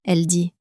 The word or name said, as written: elle dit